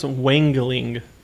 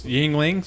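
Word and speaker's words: Yuengling: I think it's pronounced Wangling. – Don't be dumb. – Yeah. Okay. Good chat. – Anyways, brought this over. Tell us about the beer. – Well, it's Yingling.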